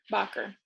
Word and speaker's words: Bacher: But it is Bach, like the composer, Bacher.